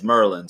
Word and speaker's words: Maryland: It's Maryland. Like, that's Merlin. Sorry, I'm pronouncing it wrong. It's Merlin, so.